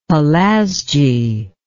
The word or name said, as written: Pelasgi